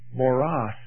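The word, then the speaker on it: moros (μωρός): The English pronunciation of it is moros, from which we get our English word moron.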